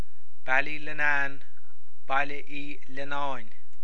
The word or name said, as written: Baile Uí Leannáin